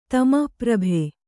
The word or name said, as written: ತಮಪ್ರಭೆ